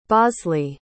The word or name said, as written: bosley